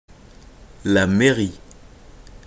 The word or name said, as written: la mairie